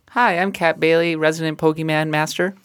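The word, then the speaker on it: Pokémon: I think the proper pronunciation is Pokemon, hence the accent grave above the E.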